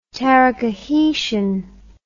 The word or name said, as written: Taracahitian